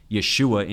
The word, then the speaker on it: Yeshua: He wrote in this letter, I know Jesus, Yeshu, which Yeshua in Hebrew is how you say Jesus, but the derogatory way to say it in Hebrew is Yeshu, which is an acronym for may his name be blotted out.